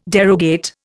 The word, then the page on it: derogate: Indian